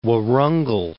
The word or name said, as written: Warangal